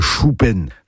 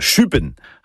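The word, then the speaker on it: chupenn: Donc on ne dit pas un choupen, non, c'est un chupen.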